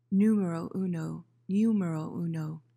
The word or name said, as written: numero uno